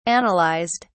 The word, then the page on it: analysed: British English